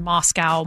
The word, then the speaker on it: Moscow: And I'm sorry, I was pronouncing it wrong. I was told by some folks on the text line, Moscow, Moscow. So now I know.